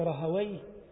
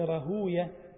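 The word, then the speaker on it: راهويه: وقال إسحاق ابن رهوي أو قل ابن رهوية، هو لغة رهوي، لكن بعض أهل الحديث يرى أن رهوي من أسماء إبليس كما وردت فيها الأثار، فقال يستحسن أن ينطق رهوية، إسحاق ابن رهوية، ومن قال رهوي صواب لغة.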